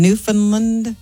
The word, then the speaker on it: Newfoundland: Newfoundland.